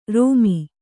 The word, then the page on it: ರೋಮಿ: rōmi